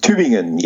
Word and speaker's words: Tübingen: I'm going to really butcher the name of this, the University of Tübingen. – Tübingen, yes. I was a student of in Tübingen, but also my PhD, and then I also went through this German torture of habilitation.